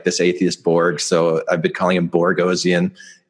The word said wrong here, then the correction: Boghossian: It's it's Boghossian.